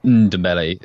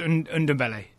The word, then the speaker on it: Ndombele: First of all, how confident are you that we should be pronouncing that N? – I think it's like a soft okay. – Ndombele. – Yeah, so you kind of half swallow it, so it's Ndombele.